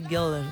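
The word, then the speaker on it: Göller: Me encanta tu pronunciación alemana, Goller. – Goller, porque tiene cremillas.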